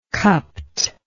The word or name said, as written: cupped